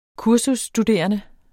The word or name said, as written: kursusstuderende